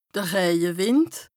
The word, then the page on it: de Rëjewìnd: Bas-Rhin; Strasbourg